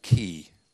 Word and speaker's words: chi: And in the Greek, lit- in Greek language, the letter X is said like key. C H I.